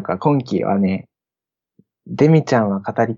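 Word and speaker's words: 亜人: アジンって書いてデミって読むんだけどね、くくりとしては日常系アニメのくくりなのかな。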